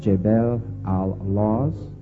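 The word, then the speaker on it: Jabal al-Lawz: The proper pronunciation is Jebel al-Laws.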